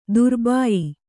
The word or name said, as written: ದ್ರಬ್ಯ